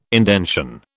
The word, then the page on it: indention: British English